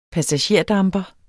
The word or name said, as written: passagerdamper